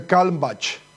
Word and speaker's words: Kalmbach: Es un apellido alemán, tengo entendido. – Ah, entonces Kalmbach. – Sí.